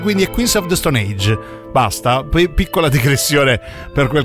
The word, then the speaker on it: Queens of the Stone Age: Insignificant Other, novità per i Queens of the Stone Age. E a tal proposito vi prego, si dice Queens of the Stone Age e non Queens of the Stone Age.